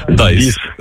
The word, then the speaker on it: dies: От пишеться D-I-E-S. Дайс.